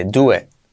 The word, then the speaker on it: do it: Fíjate, la T casi no se dice, do it. Y la I como E.